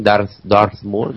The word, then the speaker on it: Darth Maul: Y este Tesai, aparte de ser un gigantón y de convertir su cuerpo en piedra, tiene una especie de lanza del tipo Darth Maul, este de, ¿no? Como lo he dicho bien, Darth Maul de Star Wars. – Sí, Darth Maul.